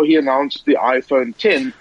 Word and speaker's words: iPhone X: And I don't know about you, Stephen, but iPhone X pronounced iPhone 10.